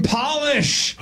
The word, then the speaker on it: Polish: Polish and polish. – Oh. – Well done. – Job. – It's the word Polish is pronounced differently when it's lowercase. It's just polish.